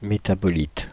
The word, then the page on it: métabolite: [me.ta.bɔ.lit]; /me.ta.bo.lit/